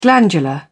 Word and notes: glandular: British English

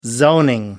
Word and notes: zoning: American English

